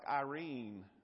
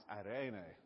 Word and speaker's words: eirene: If you're reading it on paper, it looks just like, if you're from Tennessee like me, you'd say, hey, that looks like Irene. It's not. It's eirene.